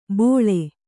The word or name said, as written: ಭೋಳೆ